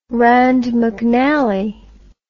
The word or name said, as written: rand mcnally